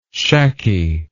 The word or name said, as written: shacky